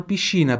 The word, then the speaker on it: piscina: Em italiano, piscina, porque em italiano, i tem som de X.